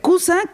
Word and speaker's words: Kooza: Cusa, que se escribe K-O-O-Z-A, está inspirado en la palabra sánscrita cosa, que significa caja, cofre o tesoro.